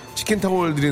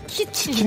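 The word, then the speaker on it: kitchen: 예, 공중파 라디오에서 치킨타월 드리는데 어디 있겠냐고요. 여기입니다. 예. 자, 치킨, 치킨, 예. 키친. 키친이 아니에요. 예. 키친입니다. 부엌이라는 뜻이죠. 부엌. 아시겠죠?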